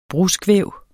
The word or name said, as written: bruskvæv